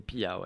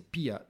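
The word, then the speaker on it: Pyhä: Tu veux bien que je parle finlandais Poids, qui s'écrit P-Y-H-A. – C'est ça. C'est bon c'est Pia, ouais. Ouais, c'est Pia.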